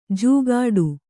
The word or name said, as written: ಜೂಗಾಡು